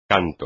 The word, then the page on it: canto: espagnol